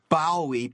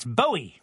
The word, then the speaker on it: Bowie: You're pronouncing his name wrong. It's Bowie, not Bowie. – In England, they pronounce Bowie, Bowie. – In Canada, they pronounce Bowie, Bowie. – I thought Canada was part of England. – Well, it's not. And even if it was, David Bowie, Bowie, is not from Canada.